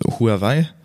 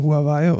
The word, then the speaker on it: Huawei: Huawei mag, äh, ist eine, die native, also die ja. originale Aussprache. – Ja, aber ich glaube, in Europa sagt keiner Huawei, sondern alle nur Huawei.